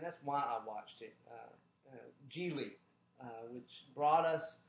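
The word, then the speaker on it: Gigli: He says it as Geely in the movie, so I'm going to go with the way the character pronounces it, because it's the character's name.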